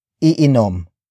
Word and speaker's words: iinom: Tayo is spelled as T-A-Y-O. It means we, including you, the focus of this sentence. After that, we have iinom. Iinom. Iinom. Iinom is spelled as I-I-N-O-M.